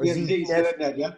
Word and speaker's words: ZDNet: You were GM of, is it ZDNet? Is that how you pronounce it? – Or ZDNet, yeah.